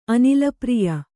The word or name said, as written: ಅನಿಲಪ್ರಿಯ